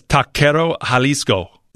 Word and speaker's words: Taquero Jalisco: My Bobram producer has some Spanish background, and he totally just corrected me in my ear. He says, Taquero Jalisco. That was better.